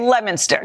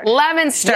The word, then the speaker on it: Leominster: So how do we say it? – Leominster. – Leominster. – Yes, but it's not spelled anything like that.